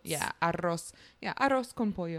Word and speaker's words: arroz con pollo: Did I say that right? – Yeah. – Arroz. Yeah. Because I know arroz con pollo. I know they do their R's differently than it is in Italian.